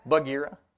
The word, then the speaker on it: Bagheera: Bagheera.